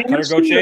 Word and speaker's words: Gauthier: Uh, yeah, Gauthier.